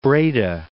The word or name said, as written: Breda